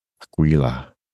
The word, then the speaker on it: Aquila: The constellation name Aquila is pronounced Aquila.